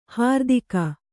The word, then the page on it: ಹರಿತಕ: kannada